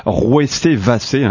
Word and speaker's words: Rouessé-Vassé: Voilà, c'est dans la Sarthe et ça se prononce Rouessé-Vassé. Hein, j'ai dit Rouessé-Vassé tout à l'heure. Je n'avais pas les accents sur mon texte.